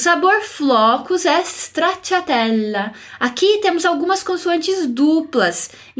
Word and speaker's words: stracciatella: Observe aqui que o S no início ele é mudo. Não digo stracciatella, mas stracciatella. Repete anche tu, repete você também.